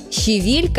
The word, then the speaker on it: щавель: Правильное ударение на второй слог. При этом вариант «щавель» звучит очень часто, настолько, что кажется нормой. Но в литературном языке это все-таки ошибка, хоть и привычная.